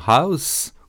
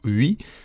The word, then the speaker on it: Huis: En effet, c'est la formation House, ou Hui, il y en a même qui le prononcent Huis, qui présenteront le 15 octobre prochain leur quatrième disque intitulé In the Face of the Unknown.